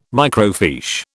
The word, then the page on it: microfiche: British English